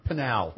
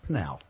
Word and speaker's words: Pinal: Penal. – No, Pinal. – Right. – It's pronounced Pinal. – Right. – Pinal, like penalty in soccer.